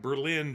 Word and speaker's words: Berlin: Now, Berlin is spelled like Berlin, Germany, but it's Berlin in Ohio.